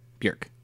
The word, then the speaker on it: Björk: How do we feel about, and I will pronounce it, Björk, because that is apparently the correct pronunciation. – Björk rhymes with jerk.